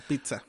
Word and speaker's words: pizza: Es, yo es lo único que sé de la gramática italiana, que la doble Z es como TS, sí. Como la pizza.